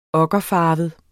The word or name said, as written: okkerfarvet